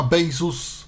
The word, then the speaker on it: Baseus: Nie wiem, no firma Bezos, pisze się Baseus, tak to wyprodukowała Mm,